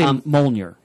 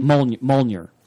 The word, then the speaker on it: Mjolnir: I always say Mjolnir. – Say it again? – Mjolnir. – More like Mjolnir. – Okay. – Although I always said Mjolnir. And actually, when we were doing footnotes on the Simonson run of Thor, we actually did find a letters page in which Walt Simonson himself said that he pronounced it Mjolnir.